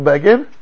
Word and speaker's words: Begin: And then they said Menachem Begin. And the right in brackets rhymes with Fagin.